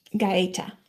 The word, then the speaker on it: Gaeta: Well, the Italian pronunciation, which would be technically correct, is Gaeta. – Gaeta. Okay. – The American pronunciation is simply Gaeta, like the word gate with an A at the end. And I'm fine with either.